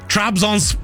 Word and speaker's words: Trabzonspor: Trabzonspor.